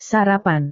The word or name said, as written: sarapan